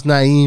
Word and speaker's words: Niim: The way this nigga spelled now, and I know it's pronounced Naeem because there's no other way to pronounce this name. N-I-I-M.